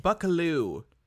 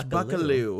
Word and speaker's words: Buccleuch: Buccleuch. It's pronounced Buccleuch.